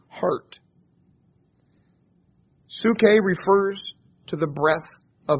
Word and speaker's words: psuche: I'll spell that for you if you like. It's P-S-U-C-H-E. It's pronounced psuche.